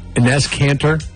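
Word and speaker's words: Enes Kanter: Tensions escalated when Inez Kanter, am I saying that right, Gavin? – That sounds right.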